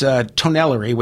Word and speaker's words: Tonnellerie: So it's Tonellerie, which is T-O-N-N-E-L-L-E-R-I-E. Spell that. Caduce is C-A-D-U-S .com.